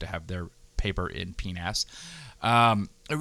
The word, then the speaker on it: PNAS: So in PNAS, which if you don't know, I'm not mispronouncing penis.